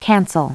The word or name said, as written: cancel